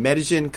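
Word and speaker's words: Medellín: And as I said before we started, I will probably be butchering this word with my Midwestern way of speaking. But you are in Medellin, Colombia. So tell me a little bit, outside of just the fun holidays, tell me about your home. – Yeah, actually that wasn't too bad, your pronunciation.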